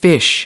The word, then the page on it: fish: American English